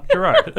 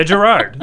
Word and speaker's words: Gerard: Gerard, Leah Gerard. – Yay! – Dylan's superior officer. – That's exactly right. It's pronounced like Gil Gerard, Buck Rogers from the Across the 25th Century.